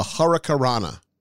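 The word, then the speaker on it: Hurricanrana: No, you said it wrong. Here, listen to him. He says it right. – Another spectacular finisher is the Hurricane Rana. Slither tries to whip... – The Hurricarana. Hurricarana.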